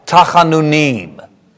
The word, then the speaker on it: Tachanunim: And you got to get the cha. Tachanunim. That's pretty good. And remember, Hebrew accents on the last syllable most of the time.